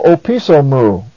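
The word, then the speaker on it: opiso mu (ὀπίσω μου): Though moo is a separate word meaning me, yet in pronunciation and identification, it is pronounced with opiso. In fact, with the proclitic and enclitic going with opiso, our example phrase literally is read, ho opiso mu. Ho opiso mu, as if it's one word.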